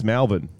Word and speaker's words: Melbourne: Foreigners like Melbourne. It's like, no, it's Melbourne.